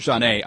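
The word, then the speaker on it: Zhané: I have a promotional kit from their first CD pronounced Janae.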